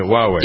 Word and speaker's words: Huawei: There are some Huawei, isn't that we pronounce it Huawei, yep. – Right? – Yep.